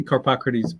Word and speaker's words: Carpocrates: Yeah, I say Carpocrates, but yeah, any way you can manage it, it's a tough one.